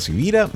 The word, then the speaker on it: Sivira: Mi nombre es Carlos Javier Sivira, mi apellido no es muy común, así que si quieres visitar mi página o perfil, pues mi apellido se escribe con S de semana y con B de Victoria.